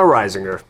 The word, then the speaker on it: Reisinger: I gotta pull my head out of my Reisinger. – Yeah, you pronounced it correctly too. – Yeah, so that's Reisinger. – It's like Rhode Island.